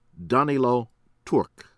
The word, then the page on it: Danilo Turk: American English